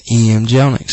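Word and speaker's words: Jellinek: David knew about the EM Jelinek Center.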